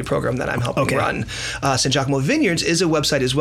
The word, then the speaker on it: Sangiacomo: Say Sangiacomo because they think of Sangiovese.